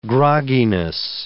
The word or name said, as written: grogginess